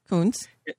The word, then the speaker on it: Coons: Yes, if you believe absolutely that the likes of Chris Coons, that how they pronounce his name in Coons. – Delaware? – Yes, Coons.